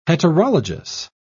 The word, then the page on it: heterologous: British English